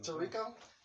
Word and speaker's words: Toriko: Torokyo? How do you say that joint? – Toriko? Yeah, Toriko. Yeah, I remember that.